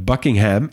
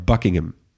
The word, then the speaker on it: Buckingham: En Buckingham is niet Buckingham, maar Buckingham.